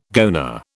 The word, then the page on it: goanna: British English